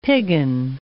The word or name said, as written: piggin